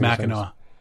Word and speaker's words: Mackinac: Yeah, it's Mackinac.